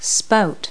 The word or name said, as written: spout